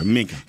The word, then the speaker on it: Minka: Minka. What'd you call him? – What, like a sinker? – You called him Finka? – Finka, okay, Minka. Sorry.